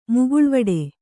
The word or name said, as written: ಮುಗುಳ್ವಡೆ